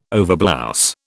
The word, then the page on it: overblouse: British English